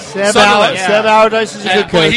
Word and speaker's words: Allardyce: Sam Allardyce? Is that how you say it? Sam Allardyce. Yeah.